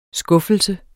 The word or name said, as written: skuffelse